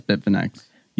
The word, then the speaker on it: Bitfinex: Yes, Bitfinex.